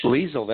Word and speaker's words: Liesl: Liesl, that's correct.